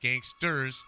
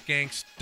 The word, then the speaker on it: gangsta: Then they came back and said, oh, our judges have said that You didn't pronounce it right. It's gangsta.